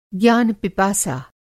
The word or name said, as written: ज्ञान-पिपासा